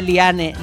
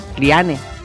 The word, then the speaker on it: Liane: Anda, que me dio una rabia el domingo, que no paraban de decir en la montonera, en vez de Liane Lipper, decían Lian, que a ver, que es alemana, Lian. Vale, alemana de Alemania. No se pronuncia igual que Diane, no, en inglés, no.